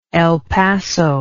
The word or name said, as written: El Paso